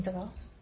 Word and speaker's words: Segel: Jason Segel. – Segal, like as in Steven Segal, is that how you pronounce his name? It's pronounced just like Steven Segal's last name.